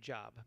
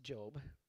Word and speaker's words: Job: Some people pronounce the name Job, but it's Job.